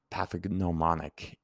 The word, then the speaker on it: pathognomonic: It comes from the Greek words pathos, again, suffering, and gnomon, meaning indicator, G-N-O-M-O-N. And I'm just going to do a little bit of a self-correction there. Pathognomonic. Pathognomonic is how it's really pronounced. That G is silent. It's hard for me.